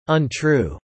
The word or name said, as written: untrue